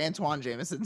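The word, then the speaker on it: Antawn Jamison: Gets picked up, it's spelled Anton, but it's Antoine. – Yeah, I'm sorry. Antoine Jameson.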